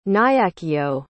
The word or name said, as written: nyakio